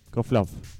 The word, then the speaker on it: Kozlov: Kozlov.